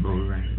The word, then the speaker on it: Boca Grande: Boca Grande.